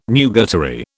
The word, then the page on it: nugatory: British English